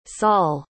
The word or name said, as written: saul